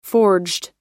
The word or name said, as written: forged